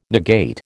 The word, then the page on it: negate: American English